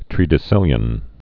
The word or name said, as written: tredecillion